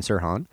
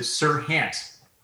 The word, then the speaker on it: Serhant: You worked extensively with a personality that is now a personality on YouTube, was previously a personality on television, Ryan Serhant. Correct? You worked with Ryan Serhant, right? – Yes. And yes. I did. And by the way, his last name is pronounced, he pronounces it Serhant.